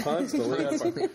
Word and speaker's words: Ponce de Leon: Ponce de Leon. – That's how they pronounce it in Atlanta. Yeah, it might be Ponce de Leon somewhere else, but Ponce de Leon in Atlanta.